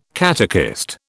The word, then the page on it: catechist: British English